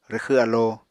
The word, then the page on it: rwxw alo: Apatani